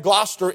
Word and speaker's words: Gloucester: But notice if you would very quickly, he was born in, that's pronounced Gloucester. Gloucester, in case you didn't know that. I used to call it Gloucester and then I had to be corrected a few times.